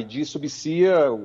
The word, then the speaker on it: ID Subsea: Eu estou dando uma olhada aqui no site da empresa, ID Subsea, essa é a pronúncia correta da empresa, né? – A pronúncia correta é ID Subsea, a gente aqui no belo e bom português chama mais de ID, né, Subsea, e é mais conhecida como ID, né?